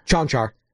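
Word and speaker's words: Konchar: This guy Conchar, who might Chanchar. – Yeah, I forgot I always pronounce it wrong.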